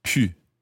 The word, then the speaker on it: pu: The past participle of pouvoir is pu, P-U, pu. Now, notice the pronunciation of that.